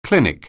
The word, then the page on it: clinic: anglais